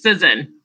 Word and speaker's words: Cizon: I hope I do your last name right, right? – No, it's Cizon.